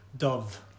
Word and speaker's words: Dubh: That's the best thing of touring is getting to know the local community, and getting to am I saying this right? Roisin Dubh. – Dove. – Dove. Okay. – Yeah. – I've been saying Dub this whole time.